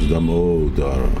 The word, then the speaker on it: Damodara: And the last question is Braja Kumar, who asks, how do you pronounce the name Damodar? He says that a Sanskrit scholar, rather than the general Damodara, that it should be the O that's emphasized, Damodara.